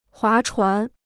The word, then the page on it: 划船: huá chuán